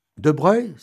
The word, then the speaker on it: de Broglie: De Breuil, ça s'écrit De Broglie, mais ça se prononce De Breuil.